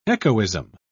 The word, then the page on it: echoism: British English